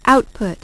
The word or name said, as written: output